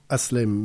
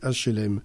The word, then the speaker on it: HLM: Alors, M. Lupi prononce HLM, mais on entend Aslem, mais c'est des HLM.